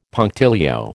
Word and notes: punctilio: American English

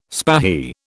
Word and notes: spahi: British English

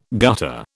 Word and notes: gutter: British English

